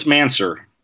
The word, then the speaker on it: Mancer: Oh, it's Mancer. – Okay, just wanted to check. Like necromancer, obviously not spelled the same.